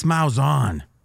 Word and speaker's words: Malzahn: This Malzahn. Is that how you say his name? – Yes. Yes.